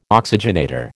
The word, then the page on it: oxygenator: American English